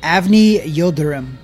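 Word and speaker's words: Avni Yıldırım: Avni Yildirim.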